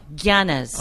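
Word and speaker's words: Yáñez: Yanez. – No, Yanez. – Oh, so Yanez. – It has a little bit of a G like lasagna.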